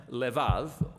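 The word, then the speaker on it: levav (לבב): Heart in Hebrew is sometimes pronounced levav, or more often in a shorter form, lev.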